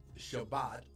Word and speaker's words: Shabbat: from Friday night to Saturday night is what God calls Shabbat, or which would be the Hebrew pronunciation, or the Sabbath.